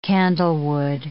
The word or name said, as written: candlewood